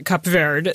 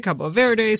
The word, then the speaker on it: Cabo Verde: One, we just heard the man himself say Cabo Verde, the right. English pronunciation. Yes. You and I are going with Portuguese, which That's is right. what people there would say, Cabo Verde, same country.